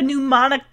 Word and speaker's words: mnemonic: And if you don't know, the word mnemonic has a silent M on the front of it. No way would I have gotten that. Who puts a silent M in front of an N?